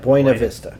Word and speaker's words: Buena Vista: Buena Vista. – Let me see it. I want to see it. – Buena Vista, see? – Yeah, that's Buena Vista.